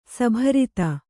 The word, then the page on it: ಸಭರಿತ: sabharita